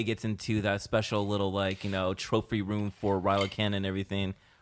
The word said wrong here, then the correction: Kylo Ren: Kylo Ren, sorry.